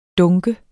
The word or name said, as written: dunk